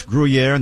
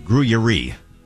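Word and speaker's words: Gruyère: Yeah, it's Gruyere, not Gruyere.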